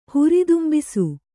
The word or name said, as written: ಹುರಿದುಂಬಿಸು